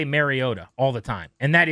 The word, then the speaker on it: Mariota: I think it's been said over the years, like Mariota, which is incorrect, and it's pronounced Mariota.